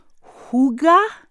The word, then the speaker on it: hygge: It's a Danish word spelled H-Y-G-G-E. So how do you say it? Hygge? Is it hygge? Mike Viking, how's my pronunciation? – I think you passed the Danish test. It's pronounced hygge, so you're close enough.